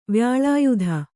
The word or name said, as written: ವ್ಯಾಲಾಯುಧ